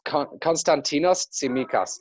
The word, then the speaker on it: Konstantinos Tsimikas: Cons, you might help me with the pronunciation of his name. You're better with these things than I am. – Yeah, it's Konstantinos Tsimikas.